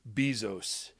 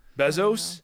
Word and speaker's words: Bezos: Bezos? – Jeff Bezos? Bezos? Is it B-E-S or B-E-Z? – It's a Z, it's B-E-Z, yeah. – Right? It's a Z. Okay.